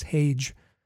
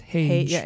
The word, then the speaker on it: Hage: And by the way, to the Montreal-based reporter who's doing the interviews for the it's Hage. It's not Hag. It's Hage. – Michael Hage. No, yeah, it's not a hard G.